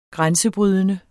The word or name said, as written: grænsebrydende